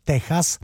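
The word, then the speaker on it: Tejas: Texas by se dalo i vyslovovat, jo, ale po španělsku, že, Texas, to je pátý album kapely ZZ Top, který vyšlo v roce 1977.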